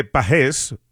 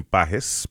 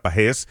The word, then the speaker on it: Pages: Y si escuchaste a José Mota y escuchas que dice de Pages, es que así es que realmente se pronuncia Andy Pages, pero obviamente Andy pues ha dejado que le llamen Pages porque ya es de costumbre, pero correctamente es como lo está anunciando y pronunciando José Mota.